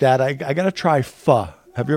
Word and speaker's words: pho: And her mom, because I never knew how to pronounce it, pho. I'm like, pho. And she's like, no, it's pho. It's pho. Pho.